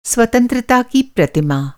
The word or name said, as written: स्वतंत्रता